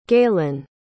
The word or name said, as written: gaylon